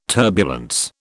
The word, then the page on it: turbulence: British English